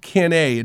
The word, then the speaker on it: CANEI: There's, a, there's, an, a, there's an acronym that really speaks to who I am as, as a teacher, and that's it's pronounced CANEI.